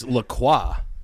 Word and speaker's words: La Croix: I actually think it's La Croix.